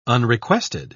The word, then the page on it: unrequested: British English